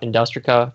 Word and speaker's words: Industrica: And then Karen, from there, can we talk a little bit about Industrica, if I'm pronouncing that correctly, and what your role was like with the company and everything that you guys had going on? – You are.